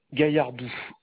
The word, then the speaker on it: Gaillardou: Gaillardou.